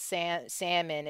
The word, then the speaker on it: salmon: Listen, the fact that we supposed to pronounce salmon as salmon don't make sense. It doesn't. – Why is the L there? – Yeah.